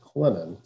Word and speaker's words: Clinton: It looks like Clinton, but they pronounce it Clennon, silent T.